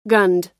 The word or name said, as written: gunned